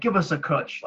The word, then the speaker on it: cwtch: C-W-T-C-H, pronounced cutch, is the Welsh word for a cuddle or hug, but also has a second meaning of a cubbyhole or cupboard, a small space in which to store things safely.